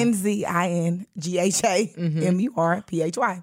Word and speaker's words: Nzingha Murphy: Nzingha Murphy. – Mm. You got to spell that because yeah, we don't even really know how to say it. – Yeah. Nzingha Murphy.